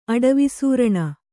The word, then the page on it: ಅಡವಿಸೂರಣ: aḍavi sūraṇa